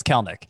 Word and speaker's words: Kelenic: It looks like Kelenic, but it's Kelnick.